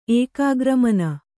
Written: ಏಕಾಗ್ರಮನ

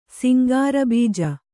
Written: ಸಿಂಗಾರಬೀಜ

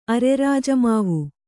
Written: ಅರೆರಾಜಮಾವು

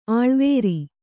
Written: ಅಳವರಿ

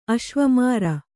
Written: ಅಶ್ವಮಾರ

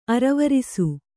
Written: ಅರವರಿಸು

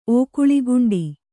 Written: ಓಕುಳಿಗುಂಡಿ

